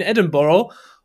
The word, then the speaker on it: Edinburgh: Ich war in Edinburgh, wie die Einheimischen es aussprechen, in Edinburgh.